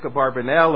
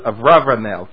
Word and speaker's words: Abarbanel: His book that came out a couple years ago on Isaac Abarbanel, or I believe as they pronounce it in the university word, Avravanel. Abarbanel is in the yeshiva world, but in the university world it's Avravanel, I believe.